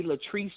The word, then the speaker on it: Latrice: My middle name is spelled L-A-T-R-I-C-E. And then on Twitter, it's Kim, not the Kimberly, just Kim, K-I-M, Latrice Jones.